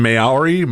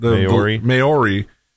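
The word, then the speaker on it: Māori: Stent said, and I had to look up how to say this because it looks like it's in Maori, whatever that. – Maori.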